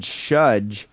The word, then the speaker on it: Shudge: The band is called Shudge, which rhymes with budge.